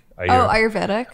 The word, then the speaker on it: Ayurvedic: Like you get these like, what are the Ayurvedic? How do you say that word? Ayurvedic? – Ayurvedic.